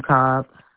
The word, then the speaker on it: Cobb: R-O-S-E-T-T-A. Last name Cobb. – C-O-M-B-S? – No, C-O-B-B. – C-O-B-B.